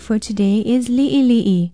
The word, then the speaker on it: li'ili'i: Be sure to include the diacritical pronunciation mark, the backwards apostrophe called the okina, and spell it L-I-Okina-I, L-I-Okina-I. It means small, little, in bits, or few. If you spell it right with the diacritical pronunciation marks, it is easier to pronounce li'ili'i.